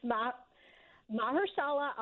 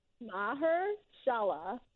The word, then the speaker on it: Mahershala: So say his name again. – It's Mahershala Ali. – Mahershala.